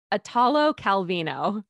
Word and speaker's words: Italo Calvino: And it was always stuff like Italo Calvino. I say it right half the time. It's Italo Calvino. There, I think that's right.